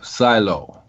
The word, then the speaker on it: Psilo: Okay, that one is called Silo, and it's spelled PSILO, Silo.